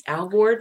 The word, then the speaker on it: Alvord: Alvord rhymes with I'm bored.